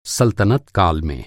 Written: सल्तनत

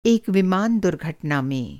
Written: दुर्घटना

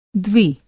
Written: द्वि

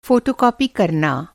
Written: फ़ोटोकॉपी

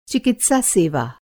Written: चिकित्सा सेवा

